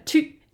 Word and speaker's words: Thy: It's called Thu in Danish. Thu. It's like a chew. It's like Gesundheit. Thu. – Thu. Okay. Thu. See? It's spelled THY.